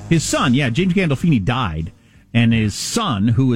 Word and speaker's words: Gandolfini: Gallofini? I don't remember his last name. – I don't know. Oh, James Gandolfini? – James Gandolfini, yeah.